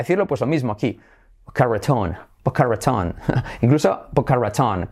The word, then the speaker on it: Boca Raton: Simplemente Boca Ratón. Suena gracioso. Boca Ratón. Boca Raton, es como español, pero pues como lo típico, ¿no?